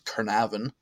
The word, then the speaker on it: Caernarfon: So Caernarfon Castle, and that is spelled Caernarfon, but pronounced Caernarfon Castle, is known as the largest and arguably the most impressive Welsh fortress.